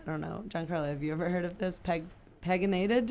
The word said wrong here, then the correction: PEGylated: did you say, drugs? – You could say that. We say PEGylated. – PEGylated, yeah, I said that wrong.